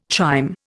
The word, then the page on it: chyme: Indian